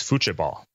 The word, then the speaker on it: futebol: I think in Brazil and in Portuguese, it's futebol. It's spelled with a T, but it's more of a CH sound, if I recall correctly.